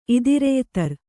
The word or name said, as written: ಇದಿರೆಯ್ತರ್